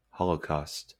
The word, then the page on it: holocaust: /ˈhɒl.ə(ʊ)ˌkɔːst/; /ˈhɑl.əˌkɔst/